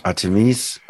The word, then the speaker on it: Artemis: Und dann habe ich eben zu diesen griechischen Göttern, zu Hera und Zeus und Artemis und also all diesen Artemis, ich weiß keine Ahnung, wie die ausgesprochen wurden. – Artemis.